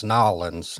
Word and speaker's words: New Orleans: So question, how do you say New Orleans? – I say it kind of like you do, like just New Orleans. – Okay, because people are like, nah, it's Nolens, and there's five different...